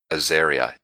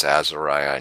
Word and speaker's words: Azariah: If you actually do, people keep mispronouncing it Azaria. It isn't. It's Azariah, and you can actually look it up, but it's from the Old Testament.